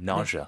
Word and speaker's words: nausea: Nausea. – 네. 다 알아듣지. 그럴 때 그죠? Nausea. 이거를 nausea 이렇게만 안 하시면 되는 거지. 네. – 스펠링은 S-E-A가 맞는데 네. – 발음은 je, je.